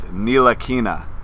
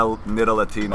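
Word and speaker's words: Ntilikina: It's Nilakina. – Alright. – Not a Nidalatina.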